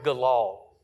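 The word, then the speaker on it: galal (גלל): The word for roll, when it says they rolled away the reproach of Egypt, is the Hebrew word galal. Galal. You can say it G-A-L-A-L.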